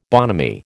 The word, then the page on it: bonhomie: American English